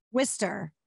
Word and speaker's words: Worcester: What is it? Worcester? – Worcester. Like Worcester. That's a terrible spelling for how you're supposed to say it.